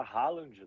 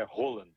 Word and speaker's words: Haaland: Aqui, o pessoal no Brasil fala Haaland, né? – O Haaland? – Mas aqui o norueguês é Haaland. – Haaland.